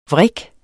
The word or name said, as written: vrik